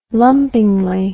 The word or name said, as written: lumpingly